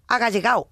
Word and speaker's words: agallegao: Es un palo agallegao, sí, lo he dicho bien.